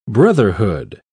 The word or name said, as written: brotherhood